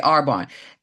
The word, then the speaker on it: Arbonne: Arbonne. – Yes, Okay, Arbonne.